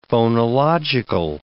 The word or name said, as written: phonological